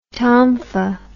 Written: tonfa

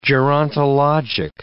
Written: gerontologic

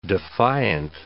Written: defiant

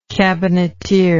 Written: cabineteer

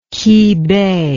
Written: kibei

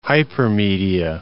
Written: hypermedia

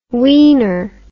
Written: weener

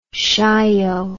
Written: Chaillot

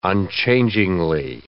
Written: unchangingly

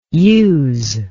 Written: youse

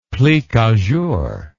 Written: plique a jour

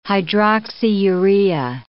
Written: hydroxyurea

